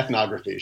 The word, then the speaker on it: ethnography: How about helping us understand, me too, for sure, what is performance ethnography? I'm probably even pronouncing it wrong. – Yeah, ethnography, sure. – Ethnography, there you go.